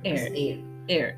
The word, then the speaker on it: Eyre: Eyre.